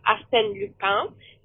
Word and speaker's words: Arsène Lupin: And it's pronounced Arsène Lupin.